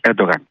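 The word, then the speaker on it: Erdoğan: Μέχρι να έρθει ο κ. Ερντογάν, που είναι η σωστή προφορά με τα τουρκικά της Ανατολίας,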